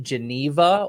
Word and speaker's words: Geneva: Depends on what language you're trying to pronounce it in. So in English, it's Geneva. French, it's Genève. Mm. And we German, because I'm in the German-speaking part in near Zurich. – Yeah. – And we just say Genf.